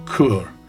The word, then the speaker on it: Chur: This time we were heading off to Chur. It's spelled C-H-U-R but pronounced K-O-O-R.